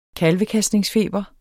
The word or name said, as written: KALVEKASTNINGSFEBER